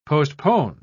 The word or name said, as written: postpone